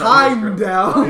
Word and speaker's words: Heimdall: Idris Elba's Heimdall.